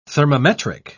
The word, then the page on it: thermometric: British English